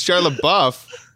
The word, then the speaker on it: Shia LaBeouf: It's Shia LaBeouf.